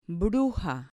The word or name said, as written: bruja